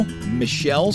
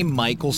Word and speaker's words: Michael: Joan Jett, Bangle, Michelle Steele. Some people say Michael Steele because of the way it's spelled, but meh.